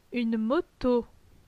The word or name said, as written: Une moto